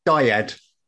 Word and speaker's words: dyad: Dyad. Is that, am I saying it correctly? Yeah, just making sure I'm getting it right. D-Y-A-D.